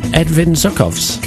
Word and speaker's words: Edvin Zuckovs: And good morning to my special guest this morning, Edvin Zuckovs.